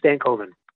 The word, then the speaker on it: Stankoven: And so that starts with our number 13 prospect, Logan Stankoven.